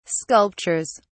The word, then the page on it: sculptures: British English